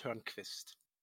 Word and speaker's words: Tørnquist: it's uh, pronounced Turnquist. Say it again. Turnquist. Torn Tornquist. – Tornquist. – Tornquist. Tornquist. Tornquist. – Tornquist. – No, still not.